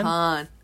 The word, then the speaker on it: Caan: Just say James Caan. Caan.